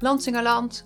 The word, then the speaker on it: Lansingerland: Het vrouwennetwerk van Lansingerland, zeg ik dat goed? – Ja, Lansingerland, Oostland eigenlijk, om het wat breder te zeggen.